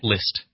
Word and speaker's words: Liszt: How do you say it? – Liszt. – Okay. There's no Z in there, but you don't say the Z. – You just say Liszt.